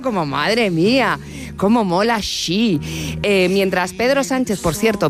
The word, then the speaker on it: Xi: Rajoy nos recuerda cómo se pronuncia. – Xi. – Xi. Es Xi, Xi.